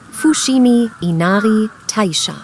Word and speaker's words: Fushimi Inari Taisha: Fushimi Inari Taisha.